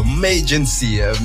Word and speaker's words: Mayagency: Donc, voilà, Mayagency, c'est comme ça qu'on le dit, hein, je l'ai bien prononcé là.